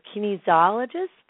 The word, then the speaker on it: kinesiologist: You are a licensed acupuncturist and applied, and I can't even say this word, kinesiologist? – Kinesiologist. – How do you say that word? – For short. Kinesiologist. – kinesiologist, and there you go.